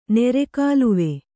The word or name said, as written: ನೀರುಕಾಲುವೆ